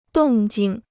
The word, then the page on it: 动静: dòng jìng